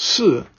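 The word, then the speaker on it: si (四): Because when you pronounce 4, which is pronounced si, it sounds like the word for death, si.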